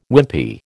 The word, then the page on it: Wimpy: American English